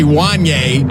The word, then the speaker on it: Wanya: Okay, first of all, you only named three people, and Wayne's name is actually Wanya. – Wanya. – You still didn't say it right. It's Wanya, not Wanya.